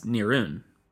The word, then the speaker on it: Nirun: As just a brief aside, the name Roran is a Chinese transliteration of the tribe's own ethnonym, pronounced in modern Mongolian as Nirun.